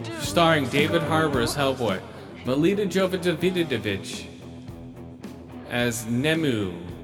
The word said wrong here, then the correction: Nimue: I couldn't get the name right, even in the movie. Nemu, the Blood Queen. Ian McShane as Professor Broom. – It's Nimue.